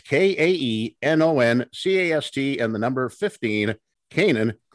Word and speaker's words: Kaenon: Kanan is the way you pronounce the sunglasses. It's spelled K-A-E-N-O-N. It's a way to make your outdoor experiences better. Kanan sunglasses made exclusively with polarized lenses for optimal clarity.